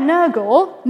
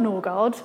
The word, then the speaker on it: Nørgaard: So this is Per Nørgaard, not Norgard.